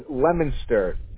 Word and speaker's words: Leominster: It is Leominster. L-E-O-M-I-N-S-T-E-R. – Leominster. – It's spelled Leominster, but it's pronounced Leominster. – So just to verify, I have L-E-O-M-I-N-S-T-E-R.